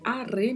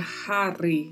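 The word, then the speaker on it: haré: A letra H não tem som, é are mesmo. Não existe a pronúncia hare, né? Como eu já ouvi às vezes algumas pessoas falarem. Não é assim que se pronuncia.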